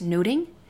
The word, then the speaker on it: nothing: But in Shakespearean English, it would have been pronounced noting.